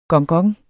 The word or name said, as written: gongong